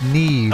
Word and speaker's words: Nev: Nev is pronounced Neve.